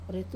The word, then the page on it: re tu: wamesa